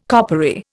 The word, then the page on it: coppery: Indian